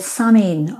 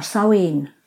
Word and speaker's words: Samhain: We are, but originally we're talking about a festival called Samhain, or Samhain, depending on your Gaelic pronunciation.